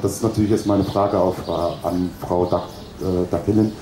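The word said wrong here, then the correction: Dağdelen: Dardelen. – Oh Gott. – Dardelen.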